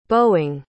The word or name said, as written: boeing